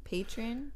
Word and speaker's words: Patreon: That's how you say it. It's like Patreon.